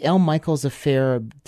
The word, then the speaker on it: L. Michaels Affair: New 7-inch from the L. Michelle's, or sorry, how do I say that? The L. Michaels Affair teaming up with Lee Fields.